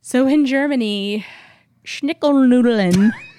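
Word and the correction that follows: Schinkennudeln: It's Schinkenudeln.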